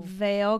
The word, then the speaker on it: Vail: But Veil, we're covering... Colorado. – Oh. – Vail, Colorado. – Okay. – Which is spelled V-A-I-L. This is V-A-L-E. So it's still Vail, Vail.